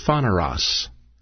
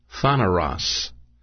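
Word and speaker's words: phaneros (πονηρός): P-O-N-E, and that's pronounced as an A, phaneros, N-E-R-O-S. That's a long E. So P-O-N, you could say A-Y-R-O-S, Phaneros, but it's actually a long E, Phaneros.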